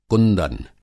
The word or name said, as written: कुंदन